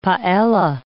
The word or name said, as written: paella